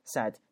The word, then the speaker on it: said: And said 这个单词请注意它的发音啊，它是一个单元音 said，然后说啥？A relation of yours is coming to see you，说你都有个亲戚啊正在来看你。